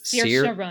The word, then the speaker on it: Saoirse Ronan: Saoirse Ronan? – Her name is Saoirse Ronan, right? – Saoirse Ronan.